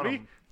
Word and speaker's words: Bonham: Yes, Bonham. I always screw up. – Just think of John Bonham. – John Bon? – John Bon Jovi? – Bonham.